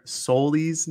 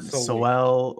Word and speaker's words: Soli: It's not Soel or Sewell or whatever we all were pronouncing for the last, what is it, August, 7 months? – Half a year. – Yeah, like the last half a year. So it's Soli.